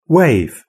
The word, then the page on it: wave: British English